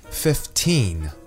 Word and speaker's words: fifteen: One five is pronounced as fifteen with the emphasis on the second syllable, whereas 50 is pronounced as 50 with the emphasis on the first syllable.